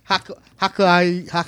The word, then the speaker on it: Hokkaido: Following last week's Nintendo Direct delay due to the earthquake in Okiohio. How do you pronounce that? – Let's do this. – I can't pronounce it. Hokkaido.